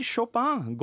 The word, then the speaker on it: Chopin: Hvis du liker Chopin godt, må du passe på å uttale det riktig. Man uttaler ikke i-en som i en i på norsk, på fransk.